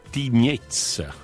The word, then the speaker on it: Tignez: La cosa interessante è che per chiamare questa prigione, per darle un nome, tu hai pronunciato Tignè, è Tignez, no? – Tignez. La Z alla fine, sì. – La Z in romancia, dove pronunciamo tutto ciò che è scritto come in italiano.